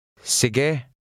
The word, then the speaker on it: sige: The Tagalog E is pronounced eh. Try to read number 3. – Sige.